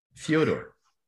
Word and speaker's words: Fyodor: But apparently, that's, according to him, that's how you pronounce his name, Fyodor. And I don't see a Y in there, but okay, you know, I respect the man's wishes.